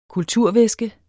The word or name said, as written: kulturvæske